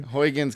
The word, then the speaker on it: Huygens: So it's actually called the Cassini-Huygens mission, and that's Huygens, H-U-I-G-E-N. – Huygens, because apparently that's the best we can pronounce it since we're not Dutch.